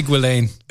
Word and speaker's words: Ghislaine: It's actually Ghislaine. – Which is very ironic. – It's actually Ghislaine.